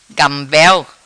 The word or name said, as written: Gambel